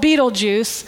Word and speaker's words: Betelgeuse: It's spelled differently than it sounds, but it's called Betelgeuse.